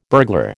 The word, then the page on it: burglar: American English